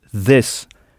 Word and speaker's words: this: « this » qui s'écrit « t-h-i-s », et il faut bien prononcer avec la langue entre les dents, « this ».